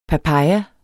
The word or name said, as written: papaya